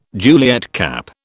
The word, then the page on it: Juliet cap: British English